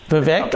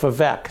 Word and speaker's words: Vivek: I have a friend who spells his name that exact way. And he calls himself Vivek. So I was just doing it based on what my friend's name was, and I thought it was pronounced the same way. So thanks for that, because I didn't know he pronounced it differently. – Yeah, I swear I heard on POTUS that like they did an interview and he like made it that it's the vague like cake or whatever.